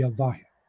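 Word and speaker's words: al-zahir (الظاهر): Some people pronounce it zahir, okay, but it's properly pronounced to be al-zahir.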